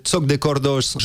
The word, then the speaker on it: Joc de Cordas: Le premier groupe qui a un nom occitan prononcé Choc de Cordos, Joc de Cordas en français, ouais.